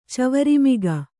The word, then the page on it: ಚವರಿಮಿಗ: kannada